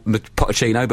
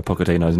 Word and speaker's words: Pochettino: Going to have to pick you up on your pronunciation of Maurizio Pochettino's name. – You call him Pochettino. It sounds like a new type of trouser.